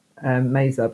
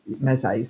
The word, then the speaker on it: meze: So you start off your meal with a full on, we pronounce it meza, but meze.